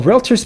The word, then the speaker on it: Realtor: Realtor, not realtor. If you're saying realtor, you've been pronouncing it wrong this whole time. Do you see an A between the L and the T? No.